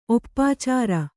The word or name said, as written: ಒಪ್ಪಾಚಾರ